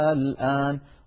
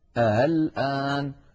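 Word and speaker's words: آلآن: دخلت عليها همزة الاستفهام فأبدلت همزة الوسط ألفا فصار النطق الآن. والسطر الثاني كما ذكرت نسهل الهمزة، هذه الحالة الثانية التي القارئ مخير فيها أن يقول الآن الآن.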